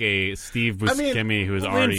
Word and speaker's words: Buscemi: But you'd, And actually, it's pronounced Buscemi. – Is it? – Yeah, that's how he says it. – What? – Yeah, it was on, I think it was on Conan that he... He's like, yeah, I actually pronounce it Buscemi, which makes sense, you know, bruschetta.